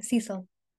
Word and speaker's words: Cecil: Cecil.